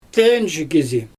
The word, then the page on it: tewnʒi kizi: Xyzyl